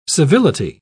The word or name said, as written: civility